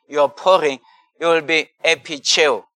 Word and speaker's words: epicheo: It's the word epicheo, you know, you can spell it E-P-I-C-H-E-O, epicheo, you know, is the word that is used there.